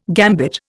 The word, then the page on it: gambit: Indian